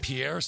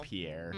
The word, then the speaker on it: Pierre: It's technically pronounced Pier, like the walkway on a beach, not like some French guy. So when I said Pierre, it was wrong. It's actually Pierre, South Dakota, but it's spelled Pierre.